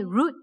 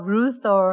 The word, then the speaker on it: Ruth: I know a friend, he called me Root, you know? Because for Chinese, it's hard to pronounce the TH. And also sometimes I call myself Ruth I know friends that call me Rufus because they couldn't pronounce the TH.